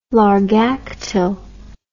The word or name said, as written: Largactil